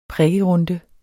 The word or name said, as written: prikkerunde